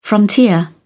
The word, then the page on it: frontier: British English